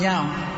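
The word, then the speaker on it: Nzau: My name is Zalman Yao. It's N-Z-A-U, but the N is silent.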